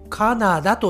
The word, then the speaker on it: カナダ: 早速やっていきましょう Let's get started まず 1 つ目の国はこちらの国になりますカナダカナダはい 1 つ目の国は日本語ではカナダと言いますカナダ、えー、英語ですとね初めがキャというね発音に聞こえますが日本語ではカナダと言いますそしてこのカナダの首都、キャピタルはこちらになります。